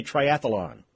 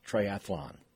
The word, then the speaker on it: triathlon: But some people say triathlon. And it's not, really. It's just triathlon.